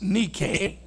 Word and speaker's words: Nike: Nike. N-I-K-E. But it's pronounced Nike. Nike.